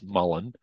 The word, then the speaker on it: Mullin: If you're reading it, it's Mullen. And I don't know if you have what the correct way to read his first name is Oisun.